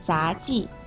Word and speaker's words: 杂技: 这里面杂技，技的声母是鸡，而杂志，志的声母是之，是卷舌音，发音的时候舌头要卷起来。